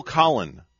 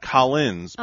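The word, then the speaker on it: Collen: Phil Collin. Not Collins, but Phil Collin or Colin.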